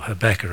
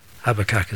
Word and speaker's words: Habakkuk: And here he's quoting a prophecy out of Habakkuk. Again, I'm going to read it, or Habakkuk, as you say here in the US. Habakkuk is the English pronunciation.